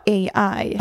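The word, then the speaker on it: Æ: And then the AE is like pronounced Ash. – AE pronounced Ash. – Ash. – That's not what Grimes said. – No. Men hon sa AI.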